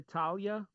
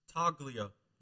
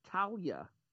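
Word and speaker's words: Taulia: Congratulations to Talia. Talia? – Taglia. You pronounce the G. – There is no G. It's T-A-U-L-I-A.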